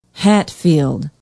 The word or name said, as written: hatfield